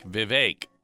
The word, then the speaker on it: Vivek: Vivek rhymes with cake. Vivek rhymes with cake. That's how it's said.